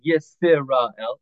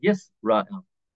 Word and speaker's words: Yisrael: So sometimes you'll notice, like the word Yisrael, there's a Sheva under the sin, and it's sort of silent. We don't pronounce it. We don't say Yisrael. We say Yisrael.